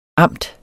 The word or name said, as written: amt